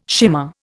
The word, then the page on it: shimmer: Indian